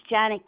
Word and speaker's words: Janet: My name's Janet Gildine. – Can you spell it for me? – Sure. J-A-N-E-T. Janet Gildine. G-I-L-D-I-N-E. D as in David, E-I-N.